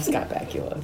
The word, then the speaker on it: Scott Bakula: I love Scott Bakula. – Because it rhymes with Dracula.